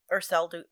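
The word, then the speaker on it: Erceldoune: The first one I found was Thomas Raymore of, how do I pronounce that? E-R-C-E-L-D. – That's why I write in phonetics. – Urseldun. – I think it's Dune.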